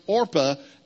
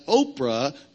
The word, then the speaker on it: Orpah: But people so consistently mispronounced Orpah as Oprah that the name stuck, and she stuck with it.